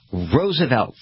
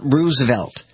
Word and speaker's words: Roosevelt: It is Roosevelt, like Rose. It is not Roosevelt. Neither of the presidents Roosevelt pronounced it Roosevelt. That's what people did who didn't know them.